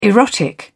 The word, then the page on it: erotic: British English